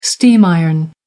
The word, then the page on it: steam iron: American English